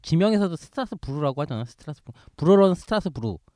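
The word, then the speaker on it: Strasbourg: 그다음에 독일어로는 슈트라스부르크 이렇게 이렇게 되는데 진짜 그렇게 해요? – 근데 가끔 이제 스트라스부르크라고 하는 경우가 있어요. 독일어도 불어도 아닌 이렇게 불어로는 이제 스트라스부르. – 그렇죠. 그 끝에 발음을 발음하지 않아요.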